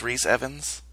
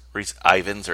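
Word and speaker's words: Rhys Ifans: So, we debated, like, the pronunciation of this, and I believe it's Reese Evans. – Oh. He's Welsh, so it's weird. – That's, yes, you know. – But I looked it up too. It is pronounced that way.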